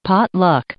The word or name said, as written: potluck